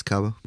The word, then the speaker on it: Cabo: Am I saying that right? – Yeah, it's Cabo.